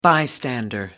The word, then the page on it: bystander: American English